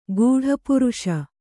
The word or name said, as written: ಗೂಢಪುರುಷ